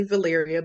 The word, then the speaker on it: Valeria: Okay, well, my name is, she said it correctly, Valeria, but I'm also better known as Miss V the Storyteller.